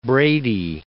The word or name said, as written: Brady